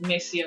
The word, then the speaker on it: Mercy: Then my LinkedIn profile is Mercy Otaro.